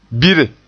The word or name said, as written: bire